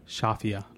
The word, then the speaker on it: Shafia: I'm your host, Michael Harlan Turkel, here today with Louisa Shafia, like mafia. That's how you pronounce it, right? – Beautifully said, Michael.